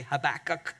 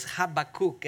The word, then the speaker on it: Habakkuk: We often say Habakkuk, but it's Habakkuk, and it means to struggle and to embrace at the same time.